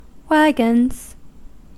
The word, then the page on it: wagons: American English